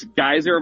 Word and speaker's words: Geiser: Sure, you can follow me on X at Real Chase Geiser. My last name is spelled G-E-I-S-E-R.